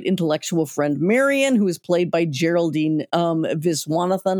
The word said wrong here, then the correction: Viswanathan: I hope I'm saying that right. – I think it's Viswanathan. – Viswanathan. Oh, I've always got the accent wrong.